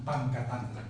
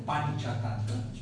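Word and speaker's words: Panchatantra: O sea, la C se pronuncia como cha. ¿No? Entonces, si yo le pongo el nombre, por ejemplo, de una corrección de cuento que se llama así, no vamos a pronunciar pan, pan catantra, sino pancha tantra.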